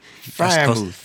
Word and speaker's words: Freiermuth: Freiermuth. – That's close enough. – Freiermuth. – Yeah. Freiermuth. Like, put him in the fryer.